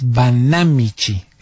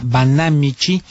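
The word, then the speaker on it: Banámichi: Y en cuanto me llegan, mientras tanto les digo que los habitantes del municipio de Banamichi, así es, Banamichi, esa es drújula la palabra, con acento en la A, Banamichi,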